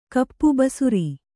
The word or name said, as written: ಕೋಪಿ